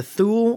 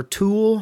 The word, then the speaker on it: Thule: and it's the Thule, or Tool, however you want to pronounce it, T-H-U-L-E, and it's their Atmos iPad Mini and iPad Air 2 cases.